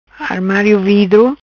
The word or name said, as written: Armario Vidro